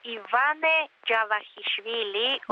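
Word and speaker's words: Ιβάνε Τζαβακισβίλη (ივანე ჯავახიშვილი): Ονομάζεται Ιβάνα Τζαβακισβίλη. – Είναι ονόματος του Ιβάνε Τζαβακισβίλη. – Δεν το είπα και πολύ καλά δηλαδή. – Είναι λίγο δύσκολο. Το Γεωργία, είναι λίγο δύσκολο να προφέρετε από τι Έλληνε.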